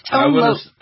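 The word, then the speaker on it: Tone Lōc: That's Tone Loke.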